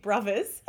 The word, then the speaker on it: Brovaz: New Flow is spelled N-U space F-L-O-W. And Big Brothers is spelled big, obviously, but Brothers is B-R-O-V-A-Z.